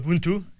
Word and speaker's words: Ubuntu: Ubuntu, yeah.